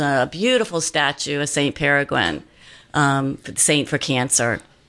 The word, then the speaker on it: Peregrine: Um, yeah, Peregrine.